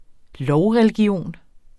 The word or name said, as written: lovreligion